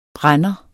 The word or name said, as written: brænder